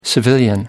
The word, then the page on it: civilian: American English